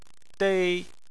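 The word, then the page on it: dei: tone 1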